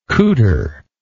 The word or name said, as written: couter